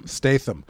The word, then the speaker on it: Statham: And it stars Alfred Pennyworth from the TV show Gotham, Tommy from Trainspotting, but almost Jason Statham. Statham? Oh, that was me. – You just become Dutch? – That was me not committing to how to pronounce that A. – Statham.